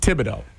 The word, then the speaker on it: Thibodeau: Thibodeau.